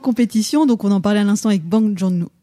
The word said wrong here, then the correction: Bong Joon-ho: euh, je, ne sais pas si tu connais la vraie prononciation d'ailleurs, un hein, Bong Joon-ho.